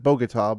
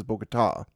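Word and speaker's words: Bogotá: I've always pronounced it Bogota, but everyone there says Bogota. The accent on the ta.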